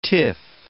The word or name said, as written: tiff